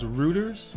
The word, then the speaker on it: Reuters: Is it pronounced Reuters? R-E-U-T-E-R-S? – As far as I know, yeah.